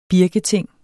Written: birketing

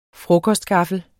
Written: frokostgaffel

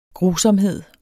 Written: grusomhed

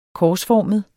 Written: korsformet

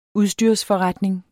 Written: udstyrsforretning

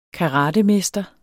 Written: karatemester

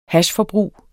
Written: hashforbrug

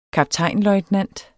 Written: kaptajn

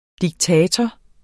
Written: diktator

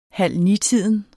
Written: halvnitiden

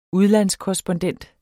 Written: udlandskorrespondent

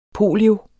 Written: Polioen

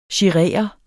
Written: girere

